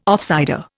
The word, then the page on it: offsider: Indian